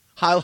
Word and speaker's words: Heil: Hail, honey. – Nope. – I'm home. – Nope. You mispronounced that word. Try it again. – Heil, honey.